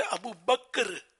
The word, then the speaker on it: ابو بکر: تو اصل تلفظ ہے ابو بکر ابو بکر, یہ رٹ لیں ابو بکر, مگر وہ چونکہ زبان پہ اتنا چڑا ہوا ہے کہ وہ نکلتا ہی ابو بکر ہے لوگوں کی زبانوں سے.